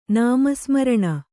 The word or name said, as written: ನಾಮಸ್ಮರಣ